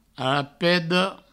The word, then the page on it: arapeda: arapedo